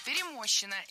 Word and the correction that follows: перемощена: – это неверно. Правильный вариант – перемощена. Ударение в этой форме падает на окончание «а» – перемощена.